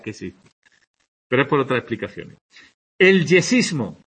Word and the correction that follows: yeísmo: Perdón, el yeísmo. Es que no sé por qué he puesto yesismo.